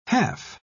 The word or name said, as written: half